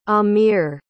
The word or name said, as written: ahmir